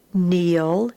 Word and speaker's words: kneel: All right, starting with lesson 122, we're going to have the N sound spelt K-N, and of course the K is silent, so you can read these with me. The K is silent, will sound the letter N. Knee, kneel,